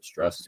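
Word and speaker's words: Strauss: They pronounce it Struss.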